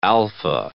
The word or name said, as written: Alfa